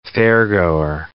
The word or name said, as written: fairgoer